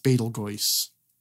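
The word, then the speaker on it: Betelgeuse: But then also, the star is technically supposed to be pronounced Betel Goyce.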